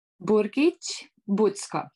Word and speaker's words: Burgic Bucko: So Emma, how do you pronounce your surname? – Burgic Bucko.